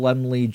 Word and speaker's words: Laemmle: The movie is filmed by Carl Laemmle Jr. See that? I said his name right.